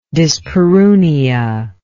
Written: dyspareunia

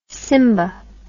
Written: simba

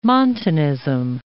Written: Montanism

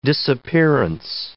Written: disappearance

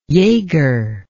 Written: yeager

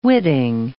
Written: witting

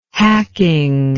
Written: hacking

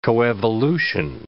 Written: coevolution

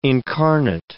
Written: incarnate

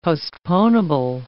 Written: postponable

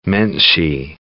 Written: menschy

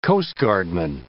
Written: coastguardman

